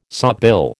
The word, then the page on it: sawbill: American English